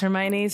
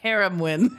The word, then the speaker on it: Hermione: But it was the South, so we didn't even know how to pronounce Hermione. So we were like, Haramwin.